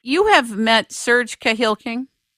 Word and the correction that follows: Kahili King: I don't know if I'm pronouncing his name right. – Serge Kahili King. – Yes.